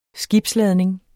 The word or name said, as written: skibsladning